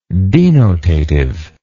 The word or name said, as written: denotativeness